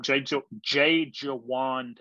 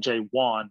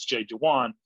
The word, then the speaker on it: Jay Jawan: Jay Jawan Townsend, that's how you pronounce it. It looks like Jay Wan, but it's Jay Jawan.